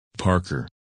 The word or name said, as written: Parker